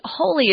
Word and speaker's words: wholly: If you disagree wholly, wholly is sort of an interesting word. It kind of sounds like we're pronouncing the word holy, H-O-L-Y, which means very sacred, but we're not. This is a completely different word with the same pronunciation, though.